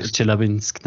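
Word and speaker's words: Chelyabinsk (Челябинск): Chelabinsk. Chelabinsk, äh, Chelyabinsk danke schön nochmal.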